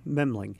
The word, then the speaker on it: Memling: Memling.